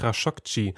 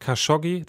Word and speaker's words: Khashoggi: Das ist Ihnen sehr wichtig, Khashoggi. Wir sagen hier oft Khashoggi, das ist aber nicht richtig.